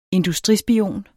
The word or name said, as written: industrispion